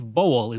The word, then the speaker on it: Bowell: Also, I've heard his name pronounced Bowel and Bowel, but I believe Bowel is the correct pronunciation, and that's the one I'm going to use.